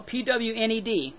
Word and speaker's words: Pwned: But we pronounce it Pwned, P-O-W-N-E-D. It's spelled P-W-N-E-D because of the transposition of the letters.